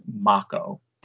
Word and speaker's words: Mako: I can only assume that we've been saying it wrong all these years, and it's actually pronounced Mako. – Did not expect that. Matt, thoughts? – Well, it makes sense, I guess, in terms of like Japanese, because right, you think of like, you know, like usually there isn't that like long A that we think of with Mako.